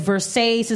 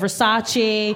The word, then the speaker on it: Versace: Like, she says Versace instead of Versace.